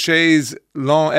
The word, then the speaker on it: chaise longue: And it's a French word, chaise longue, L-O-N-G-U-E, meaning long chair. Phonetic English pronunciation is long, says Angela Hennessy.